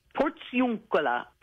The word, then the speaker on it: Portiuncula: What is the Portiuncula? Am I pronouncing it right? – Portiuncula. Portiuncula means Portiuncula.